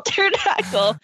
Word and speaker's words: pterodactyl: How do I spell pterodactyl? Whatever. – I mean, there's not going to be a spelling test. – Okay. I know there's a silent P at the beginning.